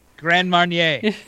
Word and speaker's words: Grand Marnier: Grand Mariner and... – Grand Marnier. – Sorry.